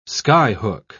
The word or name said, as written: skyhook